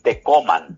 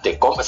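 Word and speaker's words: Tecomán: ojo con la pronunciación, de Tecoman. – Ah, Tecoman, sí, porque no lleva acento, claro. – Sí, pero son nombres náhuatl.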